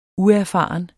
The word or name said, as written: uerfaren